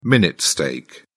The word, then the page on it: minute steak: British English